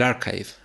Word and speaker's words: arXiv: lo pongo en una página que se llama el Archive. Ah, la conoces, ¿cierto? Es el, se escribe Arxiv, A-R-X-I-V ah, corta.